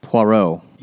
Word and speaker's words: Poirot: I think it's it's Poirot number 17 how do you say this fucking frog's name? – Poirot.